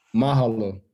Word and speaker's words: mahalo: Mahalo. It's thank you in Hawaii. Mahalo, bro. I appreciate that. – Mahalo. How you pronounce it? – Yeah, mahalo. M-A-H-A-L-O.